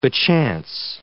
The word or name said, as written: bechance